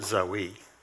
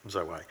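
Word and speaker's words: Zoe (ζωή): We would pronounce it Zoe. It's Z-O-E, but the E has got a long A sound on it, Zoe.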